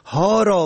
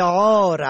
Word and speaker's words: عار: و در کلمه عار عین الفره به معنای ننگ اگر به هی دوچمه تلفظ شود هار به معنای سگ دیوانه.